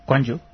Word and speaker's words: Gwangju: Gwangju.